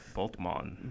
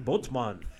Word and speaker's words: Bultmann: Bultmann, I think. I'm thinking back to my seminary days of how that name is pronounced. Like that. – Bultmann. – Bultmann.